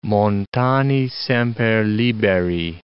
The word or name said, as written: montani semper liberi